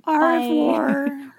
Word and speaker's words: au revoir: If you pronounce the R's, they look at you like you're an idiot. – Au revoir.